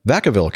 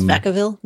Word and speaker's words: Vacaville: It's Vacaville, not Vacaville. – It's, they call it Vacaville in California. But Vaca probably would be the correct Spanish pronunciation.